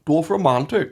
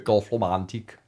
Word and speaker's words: Dorfromantik: Well, I've been playing a game called Dorfromantik. I think that's how do you pronounce it? – No, I think that's right. – Yeah, how is it? – Dorfromantik. – Dorfromantik.